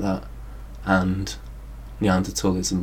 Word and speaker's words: Neanderthal: Neanderthals.